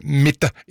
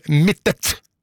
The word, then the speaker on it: Mittet: og så påpeker det Anne Soli at Mitte er et stedsnavn i Rauma kommune, og navnet har alltid blitt uttalt med en tydelig T til slutt, akkurat slik det skrives. Derfor er det ganske frustrerende å høre Mitte i stedet for Mittet.